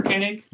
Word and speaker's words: Koenig: Walter Koenig.